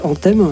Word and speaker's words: Anthem: International Anthem ou Anthem, comment on prononce? – Ici, on dirait Anthem.